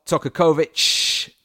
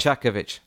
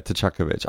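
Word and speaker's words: Čaković: Davor Tokakovic, mispronunciation gives me a chuckle every time. – He even spelt it out. – Tchakovic. – Yeah, Tchakovic. I would have got it right first time, to be fair, if the accents had been on it. But yeah, it's Tchakovic.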